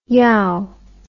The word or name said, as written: yowe